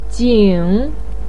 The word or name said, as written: jing3